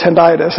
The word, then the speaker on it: hendiadys: It's what's referred to basically in Hebrew as a hendiadys. I think that's how you pronounce it, if Matt can correct me. I had professors that pronounced it six different ways, but I believe it's hendiadys.